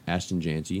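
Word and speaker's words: Jeanty: If how do you say Boise's running back? Jante? – Ashton Jante.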